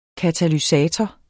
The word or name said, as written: katalysator